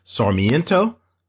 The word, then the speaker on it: Sarmiento: Douglass, and tell me if I pronounce any of these names wrong. Not, uh, English is my language I'm best at. Douglass Sarmiento. – Yes.